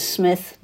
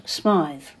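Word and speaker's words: Smyth: Although his name is spelled with a Y, not an I, it was apparently always expressed as Smith, not Smythe.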